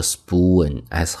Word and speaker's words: spoon: 那你喝汤的时候用的这个勺子，注意这个单词读作 spoon，s 后面的 p 读 b，这是一个清辅音浊化，soup spoon，soup spoon。